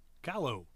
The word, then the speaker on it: Callao: How would you say that? – C A double L A O. – Calo. – Okay. I'm making it too tough then. Anyway, those two cities, Lima and Callao, demolished by an earthquake in which 18,000 people died.